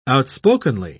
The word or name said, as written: outspokenly